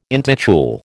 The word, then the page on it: intitule: American English